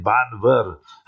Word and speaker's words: Bhanvad: Then we got a taxi, you see, from Porbandar to Banvar, B-H-A-N-V-A-D. But they say, pronounce it Banvar.